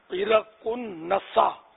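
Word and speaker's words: عرق النساء: اس کا صحیح تلفظ ہے ایرا کن نسا،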